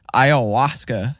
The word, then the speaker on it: ayahuasca: A-Y-A-H-U-A-S-C-A. – Yeah. Emphasis on the hua. – Hua? Hua. – You got to really feel it in your throat, dude.